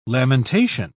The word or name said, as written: lamentation